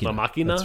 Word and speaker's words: Máquina: I think it's La Ma it's Máquina. La Máquina. That's Spanish.